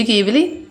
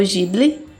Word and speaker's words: Ghibli: A Viagem de Shihiro, do estúdio Ghibli, ou Ghibli, né? Tem essas duas pronúncias.